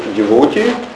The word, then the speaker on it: devotee: Я увидел, ударение стоит на втором слоге, девоте.